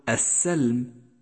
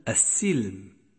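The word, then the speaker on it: السِّلم: ثامنا، احذر من اللحن في كلمة السلم، فالسين مشددة مكسورة وليست مفتوحة، فلا تقل السلم بل السلم.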